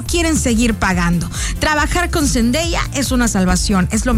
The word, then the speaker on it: Zendaya: Me gusta decir Zendaya porque ella ya dijo la otra vez en una entrevista que toda la gente le dice Zendaya, pero que significa, se pronuncia Zendaya.